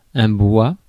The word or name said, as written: timber